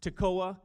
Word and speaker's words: Tekoa: He was from a town that's pronounced Tekoa.